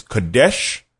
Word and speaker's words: qodesh: The transliteration is Kadesh, which is how you pronounce it.